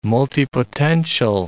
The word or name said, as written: multipotential